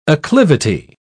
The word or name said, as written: acclivity